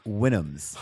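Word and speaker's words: Houyhnhnms: The Hunyuns, as they're called? Yes. – The Winnums. – It's spelled like it looks. If you say it like it's spelled, it sounds like it's pronounced Winnums.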